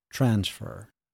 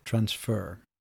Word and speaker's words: transfer: And remember, with transfer, the noun is pronounced transfer, yeah? And the verb transfer.